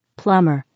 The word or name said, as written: plumber